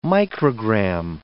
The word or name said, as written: microgram